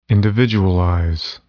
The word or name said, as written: individualize